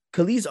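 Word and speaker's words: Kelis: Yeah, y'all heard about Kellis though.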